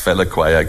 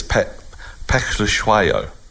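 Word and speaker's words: Phehlukwayo: and he's been pronounced Pelaclau because that looks easy. It's P-H-E-L, you know, so it looks phonetic. – Pelaclau, isn't it? – It's Pechlushwayo. It's and we tried to get this right, and I came one day and said, look, we've got to get this sorted.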